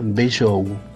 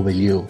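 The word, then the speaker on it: Bellou: Su apellido se escribe B larga E doble L O U, Sotiria Beliou o Beliou.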